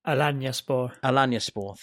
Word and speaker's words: Alanyaspor: And Alanyaspor, I'm sure I've butchered it. Alanyaspor, please, anyone who wishes to criticise my pronunciation. – Alanyaspor, thank you. Yeah. It is, you're right.